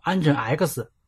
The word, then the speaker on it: Nginx: 在这里面，我必须要说一下 Nginx 的正确发音，它应该读成 Nginx，但我个人感觉 X 念起来太拗口，还是比较倾向于读作 Nginx，这也与 Unix、 Linux 的发音一致。